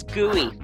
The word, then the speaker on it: GUI: His name is GUI, which is how you pronounce G-U-I. It's the acronym for Graphical User Interface.